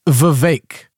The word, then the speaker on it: Vivek: Oh, it's Vivek, because it rhymes with cake.